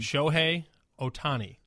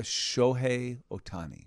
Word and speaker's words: Shohei Ohtani: How do you say that guy's name? – Shohei Ohtani. – All right, everybody, Shohei Ohtani. I've seen it, and I know he's a thing, but I S-H-O-H-E-I, Shohei. – Shohei Ohtani. – O-T-A-N-I.